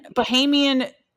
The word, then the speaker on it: Bahamian: And he owes like an entire Bohemian. Is that how you say it? – What? – Bahamian like island.